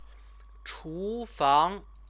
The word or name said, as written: chufang